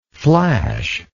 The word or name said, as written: flashless